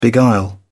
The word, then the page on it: beguile: British English